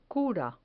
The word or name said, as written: cura